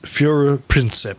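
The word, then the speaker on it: Führerprinzip: In German, it's pronounced Fuhrer Prinzip.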